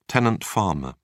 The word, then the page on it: tenant farmer: British English